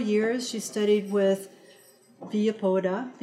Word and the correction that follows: Villoldo: Alberto Villoldo.